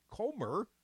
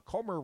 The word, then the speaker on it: Comer: Jim Jordan, who's expected to become the chair of the House Judiciary Committee and other Republicans on the Oversight Committee, Comer, rhymes with Gomer, said Republicans have made connections between the president's son, Hunter Biden, and the president that they believe requires further investigation.